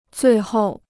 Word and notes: 最后: zuì hòu

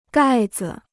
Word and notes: 盖子: gài zi

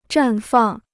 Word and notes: 绽放: zhàn fàng

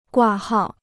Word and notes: 挂号: guà hào

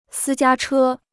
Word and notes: 私家车: sī jiā chē

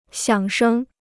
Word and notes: 响声: xiǎng shēng

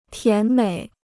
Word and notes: 甜美: tián měi

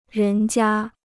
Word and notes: 人家: rén jia

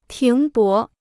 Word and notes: 停泊: tíng bó

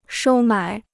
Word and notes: 收买: shōu mǎi

